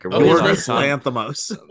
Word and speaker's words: Yorgos Lanthimos: Like Yorgos Lanthimos.